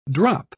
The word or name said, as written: drop